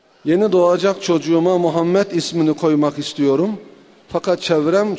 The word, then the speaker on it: Muhammed: Normalde mim fethayla yani üstün değil. Damme ile yani ötre. Muhammed yani anlayacağınız. Bu böyle okunur. Mim, ha, mim, del, Muhammed diye okunur Arapçada.